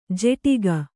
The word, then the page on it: ಜೆಟಿಗ: jeṭiga